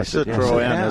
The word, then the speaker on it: Citroën: Yeah, Citroën is how they pronounce them.